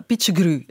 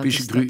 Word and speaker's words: Pichegru: Ja, Pichegru. Ik wist niet hoe ik het moest uitspreken. – Ja, Pichegru, ja.